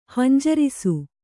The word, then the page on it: ಹಂಜರಿಸು: hanjarisu; kannada